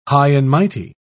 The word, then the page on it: high-and-mighty: British English